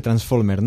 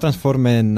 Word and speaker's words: Transformer: És difícil de pronunciar. – Sí, Transformer. No transformen, no transformen-se i avancen.